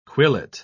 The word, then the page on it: quillet: British English